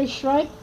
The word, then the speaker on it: Schreib: Werner Schreip? How do you spell it? S-H-R... – S-C-H-R-E-I-B.